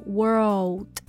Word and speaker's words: world: Superman 对 world 这个单词要注意中间的这个 l 的发音，先发 word，然后把舌头勾到你的这个天花板，然后发出这个 l 的音。 world 先可以慢读它，然后再加速。